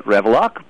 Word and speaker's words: Rewilak: And she said, "Well, we say it Revlock, but it's spelled R-E-W-I-L-A-K."